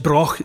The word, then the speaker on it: Brough: And the Broch of Bursay, it's spelt B-R-O-U-G-H, pronounced Broch.